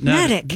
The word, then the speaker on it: Natick: It rhymes with Medic, according to this. – Natick. – Natick, not Natick. – Netic. It sits on the small Nubble Island. – What? – Nubble. N-U-B-B-L-E.